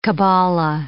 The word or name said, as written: cabala